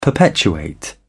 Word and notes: perpetuate: British English

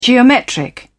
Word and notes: geometric: British English

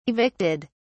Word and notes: evicted: British English

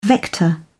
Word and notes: vector: British English

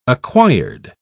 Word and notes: acquired: British English

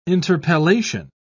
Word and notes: interpellation: British English

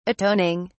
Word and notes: atoning: British English

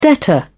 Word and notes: debtor: British English

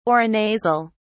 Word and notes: aurinasal: British English

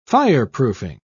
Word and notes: fireproofing: British English

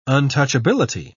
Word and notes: untouchability: British English

